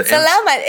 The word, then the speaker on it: salamat: Now, significantly, that is, that's the Salamat is basically Malay. – The emphasis is on the second syllable.